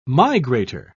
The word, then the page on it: migrator: British English